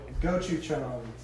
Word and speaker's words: gochujang: Have you ever heard of gochujang? It's like a Korean red chili paste. – I'll show you. – No, I haven't. Wait, say it again. – Gochujang.